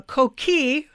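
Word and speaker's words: coquí: What is fascinating is when they're in Puerto Rico, instead of being children, they're a little pig, a coqui, who is a Yes, I said it right.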